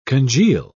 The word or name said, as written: congeal